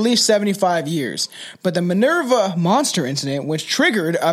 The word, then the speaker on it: Minerva: It's going to be a crazy, crazy case that I've, the Minerva monster is how you say it, right? – Minerva. Yes. I don't know how you were saying it before, but it was fucking dumb.